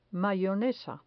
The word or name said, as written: mayonesa